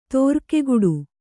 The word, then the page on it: ತೋರ್ಕೆಗಿಡು: tōrkegiḍu